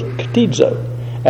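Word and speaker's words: ktizo (κτίζω): Greek word, and I've already pronounced these words for you, but it's create, and it's the word ktizo in Greek. Those are Greek letters up there, but I can just tell you that you pronounce those ktizo.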